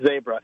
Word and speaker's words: zebra: We pronounce it wrong. It's not zebra. It's zebra.